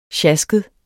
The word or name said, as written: sjasket